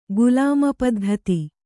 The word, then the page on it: ಗುಲಾಮಪದ್ಧತಿ: gulāma paddhati